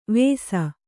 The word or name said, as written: ವೇಸ